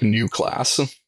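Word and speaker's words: Neue Klasse: Is it, how do you pronounce that? I'm not up on my German. – I think it's roughly pronounced like New Class.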